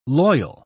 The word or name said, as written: loyal